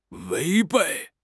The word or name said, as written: 违背